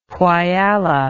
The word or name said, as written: Whyalla